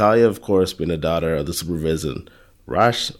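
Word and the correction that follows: Ra's: Raz yeah, Raz.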